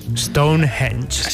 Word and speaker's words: Stonehenge: Stonehenge.